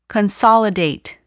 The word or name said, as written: consolidate